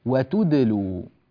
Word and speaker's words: وتدلوا: فبالتالي عندما نقرأ نقول وتدلوا بارك الله فيكم، فلا نقول وتدلوا ونبقي الشفتين مضمومتين عند هذا النطق، فلا نقول وتو، انظروا إلى الشفة، عندما أقول تو ضممت الشفتين، دو قلقلت وأنا أضم الشفتين، غير صحيح، حرف ساكن، لماذا نضم الشفتين معه؟